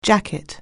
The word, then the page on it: jacket: anglais